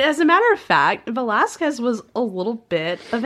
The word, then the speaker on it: Velázquez: Velasquez, or as the Habsburgs would have pronounced his name, Velazquez.